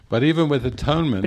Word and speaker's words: atonement: It is not atonement. – No es atonement. – It is at-one-ment.